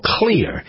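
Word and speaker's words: Xlear: So along comes a fairly new product called Clear, but it's spelled X-L-E-A-R. X-L-E-A-R, the natural sinus care system that hydrates your nose all day long.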